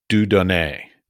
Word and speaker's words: Dieu Donné: All right, so let's get to Dieu Donné. Did I pronounce it correctly? – Very good. Yes.